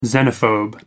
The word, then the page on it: xenophobe: American English